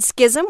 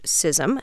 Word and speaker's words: schism: The word schism, which was originally spelled S-C-I-S-M-E in English, is traditionally pronounced schism. However, in the 16th century, the word was respelled with an initial S-C-H in order to conform to its Latin and Greek forms. From this spelling arose the pronunciation schism. Long regarded as incorrect, it became so common in both British and American English that it gained acceptability as a standard variant.